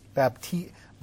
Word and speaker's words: baptizo: The English word baptize, or to baptize, is from the Greek word baptismo. Baptizo. You never say it right.